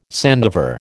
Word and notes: sandiver: American English

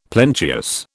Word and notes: plenteous: British English

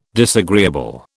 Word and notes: disagreeable: British English

